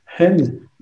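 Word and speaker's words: hen (חן): Et encore une fois, le mot grâce euh, c'est un, en hébreu, c'est un mot de deux lettres qui se prononce hen, H-E-N, et qui veut dire faveur, qui veut dire propitiation.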